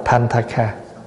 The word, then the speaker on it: Panthaka: Thì tiếng Phạn á, chữ Panthaka nghĩa là một người đi đường hay một người lữ khách, đọc là Panthaka.